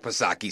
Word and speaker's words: Psaki: Oh, well, then I stand corrected, Jen Pasirko-back Psaki, silent P, of course, because then it's okay, right?